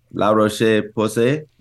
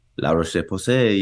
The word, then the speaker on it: La Roche-Posay: La Roche-Posay. – There you go. – See, was that French? – La Roche-Posay, yes, French.